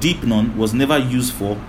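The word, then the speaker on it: deipnon (δεῖπνον): Is the word deipnon. It's spelled D-E-I-P-N-O-N. Deipnon is spelled D-E-I-P-N-O-N.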